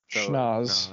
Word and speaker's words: schnoz: Snoz. – Schnoz. Gosh. – So I speak differently.